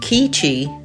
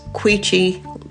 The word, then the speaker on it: Queechee: Queechee is often pronounced Keechee. It is Queechee. That Q-U sound as in quick, not Q-U as in French way, K.